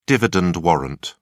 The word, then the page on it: dividend warrant: British English